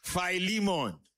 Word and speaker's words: Philemon: It's spelled with, not with an F, with a P. P-H. P-H-I-L. Philemon.